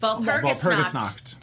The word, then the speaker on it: Walpurgisnacht: The Walpurgisnacht. – Walpurgisnacht.